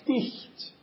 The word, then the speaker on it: knight: This was originally actually pronounced Knecht. And this is very close to the German, or it's actually the same word as the word Knecht.